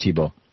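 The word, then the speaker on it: Thibeault: I don't have it right in front of me, but it's like, you know, it's the French spelling that is pronounced Tebow.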